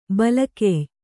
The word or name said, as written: ಬಲಗೆಯ್